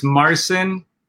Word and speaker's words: Marson: So it's Marson, which is Polish, and Teodoro, which is actually Greek.